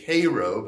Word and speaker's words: Cairo: You can go to as we say, Cairo, but it's pronounced Cairo. It's down in South Georgia.